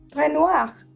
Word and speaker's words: Renoir: That's not how you say its name. – How do you say it? – Renoir.